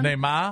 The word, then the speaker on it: Neymar: Give me it again. – Neymar. – It's almost a Nomar. – Neymar. Yeah. Neymar.